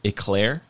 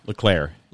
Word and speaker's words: Leclerc: You said his last name is Eclair? – Leclerc. – Leclerc. – Not like Eclair, like the French thing, but Leclerc.